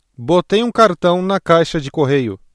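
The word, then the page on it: caixa de correio: Brazilian Portuguese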